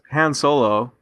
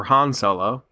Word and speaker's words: Han Solo: But it's controversial because Han Solo, or Han Solo, see, that's said both ways in the movie too.